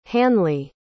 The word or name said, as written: hanly